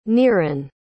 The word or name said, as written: niran